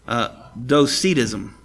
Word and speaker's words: Docetism: It is notable that one of the earliest Christological heresies, I don't know how to say this, D-O-C-E-T-I-S-M, Docetism, Docetism,